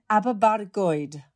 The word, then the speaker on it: Aberbargoed: Am I getting my pronunciation right? You were born in Aberbargoed. – Oh, I love the rolling of the R, Gaby. That really is. That's spot on, my love.